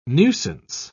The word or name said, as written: nuisance